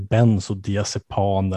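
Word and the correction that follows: bensodiazepiner: eller något sånt där. Jag, jag kan inte uttala Benzodiazepiner heter det, säger jag.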